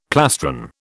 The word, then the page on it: plastron: British English